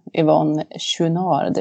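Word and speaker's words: Yvon Chouinard: Framförallt kanske tillsammans med grundaren av Patagonia, Yvonne Chouinard. Uttalar jag det rätt nu? – Ja, du uttalar det hyggligt rätt.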